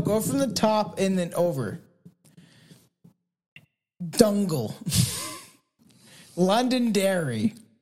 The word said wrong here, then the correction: Donegal: Dongle? No, that's Donegal.